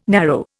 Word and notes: narrow: Indian

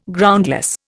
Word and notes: Groundless: Indian

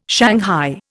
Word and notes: shanghai: Indian

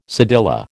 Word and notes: cedilla: American English

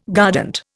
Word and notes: guardant: Indian

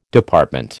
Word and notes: Department: American English

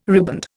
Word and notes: riband: Indian

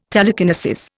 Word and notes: telekinesis: Indian

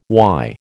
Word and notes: why: American English

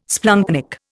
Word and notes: splanchnic: Indian

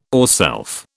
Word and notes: ourself: British English

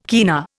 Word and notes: kina: Indian

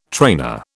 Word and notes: trainer: British English

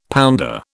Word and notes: pounder: British English

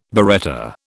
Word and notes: biretta: British English

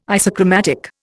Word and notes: isochromatic: Indian

